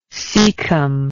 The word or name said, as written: caecum